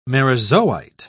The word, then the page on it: merozoite: British English